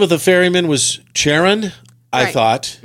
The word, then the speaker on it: Charon: It is pronounced Karen. – No, it's not. – The ferryman is pronounced Karen.